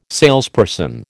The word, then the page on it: salesperson: American English